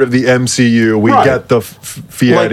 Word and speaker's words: Fieri: We got Guy Fieri.